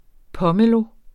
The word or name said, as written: pomelo